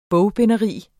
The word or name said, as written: bogbinderi